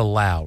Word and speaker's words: Lau: So remember, this is the Lau, rhymes with now. This looks like low.